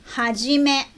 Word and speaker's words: 一: Tem o nome de menino. Hajime. Escreve assim, ichi. E a pronúncia é hajime.